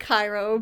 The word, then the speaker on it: Cairo: Okay, so it's spelled like Cairo, but if you talk to someone from there, yeah, it's Cairo.